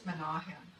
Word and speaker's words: Menachem: Am I mispronouncing that? Somebody, am I butchering that name? No, you can correct me. Correct me. – Menachem. – What? – Menachem. – Good. Thank you.